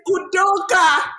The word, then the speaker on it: Udoka: They're like struggling to say his name. It's Udoka.